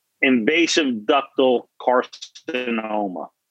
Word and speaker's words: invasive ductal carcinoma: Invasive ductal carcinoma.